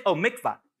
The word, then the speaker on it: mikvah: The mitzvah. Is that how you say it? Mitzvah. Everybody say mikvah.